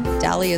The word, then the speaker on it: Dalley: Dalley is spelled like Valley with a D.